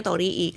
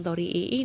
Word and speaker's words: Torii: I-nya sih dua gitu, tapi kayaknya bacanya Torii, karena kalau Tori setahu gue artinya ayam gitu kan.